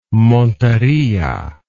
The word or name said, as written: Montería